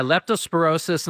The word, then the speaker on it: leptospirosis: It's leptospirosis. I said that right. Yes. – Yeah, leptospirosis.